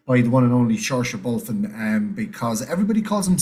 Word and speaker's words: Saoirse: I just think of the shore, you know, Saoirse. And I know how weird that sounds, but that is the correct pronunciation of it.